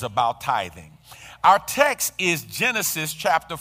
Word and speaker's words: tithing: Some people mispronounce it and call it tying, but it's not tying, it's tithing.